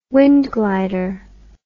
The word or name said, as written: wind glider